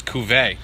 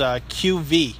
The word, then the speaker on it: cuvée: See, that's the way I was saying it, but I listened to many pronunciations today online, and it was QV. – Really? – Is the American pronunciation of it.